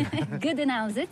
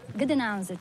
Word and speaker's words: Gdańsk: Reprononce-le parce que... – Gdańsk, c'est le nom slave. Gdansk. – Gdansk, que nous, Français, prononçons en disant Danzig.